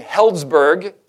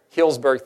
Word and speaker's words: Healdsburg: They had a camp meeting there in Healdsburg, California. Is that where it was? Somebody laughed. Did I pronounce it wrong? Healdsburg, thank you.